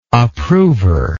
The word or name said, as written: approver